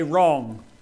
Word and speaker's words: wrong: Number two, it requires a wrong. Silent W, I know.